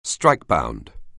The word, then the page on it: strikebound: anglais